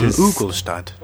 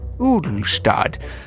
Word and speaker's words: Ugelstad: Ugelstad. – Dr. John Ugelstad. – Yes, Dr. John Ugelstad.